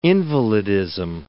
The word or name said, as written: invalidism